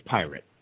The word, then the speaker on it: Prit: We say Pirate. – Pirate? – Yeah. – Okay. P-R-I-T.